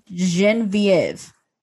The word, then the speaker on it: Genevieve: It's spelled like Genevieve, but they pronounce it Genevieve.